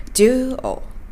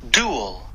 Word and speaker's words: dual: dual というふうに言います。 dual はい、今のは辞書の発音です。